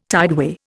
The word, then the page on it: tideway: Indian